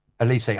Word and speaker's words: Olise: Olise had undone the good work.